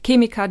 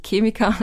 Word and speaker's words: Chemiker: die irgendwie Marken gegründet haben oder Chemiker, die dahinter sitzen. Ich, übrigens, ich bin Österreicherin, deswegen sage ich Chemiker, nicht Chemiker.